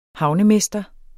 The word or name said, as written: havnemester